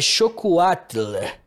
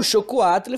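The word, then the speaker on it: xocolatl: Na verdade, a pronúncia, eu posso estar pronunciando errado, mas é com X. É chocoatle.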